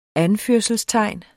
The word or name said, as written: anførelsestegn